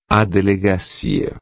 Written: delegacia

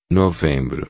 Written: novembro

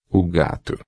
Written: gato

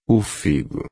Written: figo

